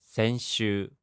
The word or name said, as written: senshuu